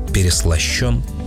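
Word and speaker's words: переслащён: Я сразу полез проверить себя на ударение. Все верно, переслащен. Если есть «ё», она ударная. А вот в женском и среднем роде нет «ё». Поэтому там «переслащена» и «переслащено».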